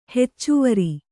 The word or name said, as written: ಹೆಚ್ಚುವರಿ